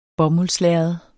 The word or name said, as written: bomuldslærred